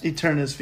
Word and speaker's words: Eternatus: Did I say that right? Eternatus VMAX. – Yeah.